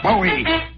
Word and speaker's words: Bowie: They're up and running Monday, Friday, January 11th at Bowie Race Course. Bowie Race Course. By the way, it says in here, rhymes with Dewey.